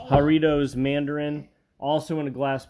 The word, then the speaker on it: Jarritos: Oh, Jarrito. I think it's pronounced Jarritos. I think it's a J and H J. It's Jarritos.